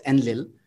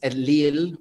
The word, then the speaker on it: Enlil: And Western societies, or in the language, it's called as Enlil. But in fact, it's Enlil with an emphasis on the il.